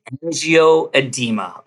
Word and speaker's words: angioedema: She has hereditary angioedema.